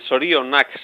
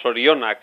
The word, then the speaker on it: Zorionak: la pronunciación exige que la segunda sílaba tenga la fuerza tónica más importante y la última, una como más débil, Sorionak sería. Y encima decimos Sorionak, encima, que es que ya es el desastre de los desastres, ¿no?